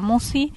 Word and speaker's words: Musci: Marisa Musi. – ¿Cómo se escribe Musi? – M-U-S-C-I.